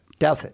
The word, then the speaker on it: Douthat: She is Ross Douthwaite. How do you say it? – Douthat.